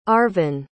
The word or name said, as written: Arvon